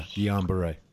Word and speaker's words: Guillain-Barré: That is Guillain, I can't pronounce French words, but I think it's Guillain-Barré syndrome. – Yeah, Guillain-Barré.